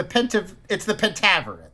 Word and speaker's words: Pentaverate: The Pentaverate. I don't think it is. It's the Pentaverate. Thank you. You said it wrong and it got me...